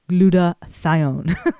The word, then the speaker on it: glutathione: So today we go into this powerful antioxidant, which is pronounced glutathione.